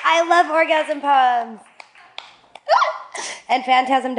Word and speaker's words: Phantasm: Phantasm rhymes with orgasm.